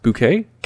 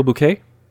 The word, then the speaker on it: Bouquet: I really dug, is it pronounced Bouquet? Carol Bouquet? – Yeah, I think so.